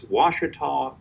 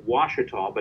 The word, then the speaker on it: Ouachita: It's an Indian name, and it's pronounced Wachita, but it's spelled O-U-A-C-H-I-T-A. And when people just look at it, they'll say, ooh, I cheated, try to come up with all sorts of pronunciations, but it's Wachita.